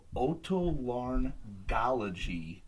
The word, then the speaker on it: Otolaryngology: Otolaryngology,